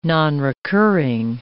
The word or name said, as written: nonrecurring